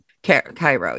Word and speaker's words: Cairo: Cairo. Yes. That's how you pronounce it.